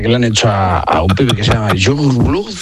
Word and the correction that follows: Youngblood: Pero luego, ¿cómo se pronuncia? – Youngblood.